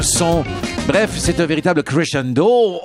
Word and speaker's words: crescendo: Mais le crescendo, la belle, la définition de Grégory Charles d'un crescendo.